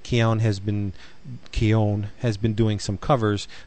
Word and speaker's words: Keown: Keown? – Keown.